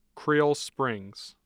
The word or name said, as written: Creal Springs